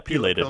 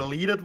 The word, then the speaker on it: pileated: And it's a how does do you pronounce it? Pileated woodpecker. – Yeah, pileated. – Pileated woodpecker. Yeah. Pileated. Okay, pileated woodpecker.